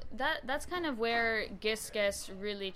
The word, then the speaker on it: Gisges: You pronounced it right, so it sounds like Gis and Guess. It stands for Give Some, Get Some. And so, what Gisges is,